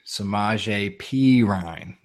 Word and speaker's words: Samaje Perine: Samaje Perine, if I'm saying that correctly, finally, because I think that was pretty good.